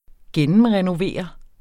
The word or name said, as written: gennemrenovere